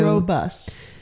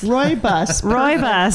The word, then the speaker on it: rooibos: Robust. – It's actually, think of like a guy named Roy driving a bus. – Roy bus.